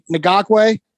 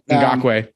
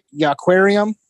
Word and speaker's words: Ngakoue: The Ngakoue, which Zach was pronouncing it. – Ngakoue. That was pretty bad. – Zach was like, Yannick Yaquarium.